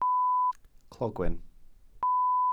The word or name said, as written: clogwyn